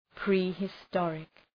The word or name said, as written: prehistoric